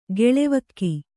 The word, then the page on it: ಗೆಳೆವಕ್ಕಿ: geḷevakki